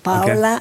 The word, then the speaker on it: Paola: und jetzt frage ich Sie aber, wie spricht man Sie richtig aus? Denn Sie werden ein Leben lang schon mit Paola angesprochen. Ich kenne die Aussprache anders. – Sie auch. – Das heißt, ja. Zu Hause hat man zu Ihnen Paola gesagt.